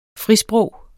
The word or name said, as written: FRISPROG